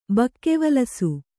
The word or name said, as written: ಬಕ್ಕೆವಲಸು